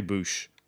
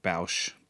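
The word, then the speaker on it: Bossk: I wonder how other people pronounce that character's name. I say Boosh. I've heard some people say Bausch, but the mighty Boosh it is to me.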